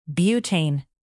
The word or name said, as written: butane